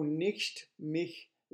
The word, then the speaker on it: nicht: O nicht mich liebe. Be careful it's not Nick, like N-I-C-K, the name, and like Nick, nicht mich, but nicht mich liebe.